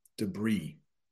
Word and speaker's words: debris: The next one is debris. Debris. That is how that is pronounced. I know there's an S at the end, but we pronounce it debris.